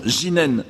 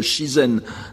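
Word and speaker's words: jinen (自然): Donc, prononcer au Japon « jinen » dans un contexte bouddhique, parce que le japonais fait très clairement la différence entre le shizen nature actuel et le shizen bouddhique, qui est alors prononcé « jinen », je n'insiste pas là-dessus.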